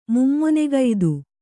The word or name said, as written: ಮೂಮೊನೆಗೈದು